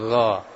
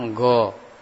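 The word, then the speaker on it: gha (غ): Kebanyakan kalau anak-anak kita atau mungkin kita yang belum mengenal makhraj ini, biasa mereka bacanya go, go gitu ya. Seringnya bacanya go. Ah. Itu kurang tepat.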